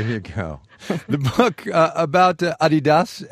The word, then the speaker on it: Adidas: You've been saying Adidas, as in Adidasler, Adidas. Over here we say Adidas. Which one's right? – Oh, definitely the European pronunciation, and it's really strange.